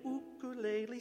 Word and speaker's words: ukulele: If you don't stop soon, it won't be long till you're writing an ukulele song like me. Ukulele, that's how it's actually pronounced. But you probably already knew that.